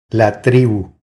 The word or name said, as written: La tribu